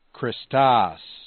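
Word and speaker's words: Christos (Χριστὸς): So, for example, it's Christos, with the grave accent on the ultima.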